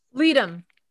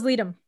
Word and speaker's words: Ledum: I don't know how to pronounce it. L-E-D-U-M. – Leadum. That was Leadum.